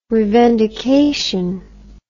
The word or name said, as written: revendication